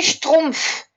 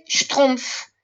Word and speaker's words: schtroumpf: Y pitufos, no se dice pitufos en francés, se dice schtroumpf. Mira qué cómico. Pitufo y en francés se dice schtroumpf.